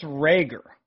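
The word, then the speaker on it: Reagor: Jalen, is it Rager? – I believe it's Rager.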